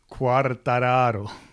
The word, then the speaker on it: Quartararo: Quartararo.